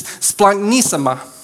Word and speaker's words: splanchnisma: If we were to look at the Greek word for compassion, I'm going to try to pronounce it correctly, it's splanchnisma. Kind of fun to say, it kind of sounds like you're sneezing.